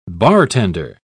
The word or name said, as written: bartender